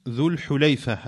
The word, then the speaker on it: ذو الحليفة: Donc ici, Cheikh Al-Bassam dit ذو الحليفة, qui se prononce ذو الحليفة avec une dhamma sur le ha et une fatha sur le lam, qui est le diminutif de halfa.